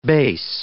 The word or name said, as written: base